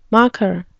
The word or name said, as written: macher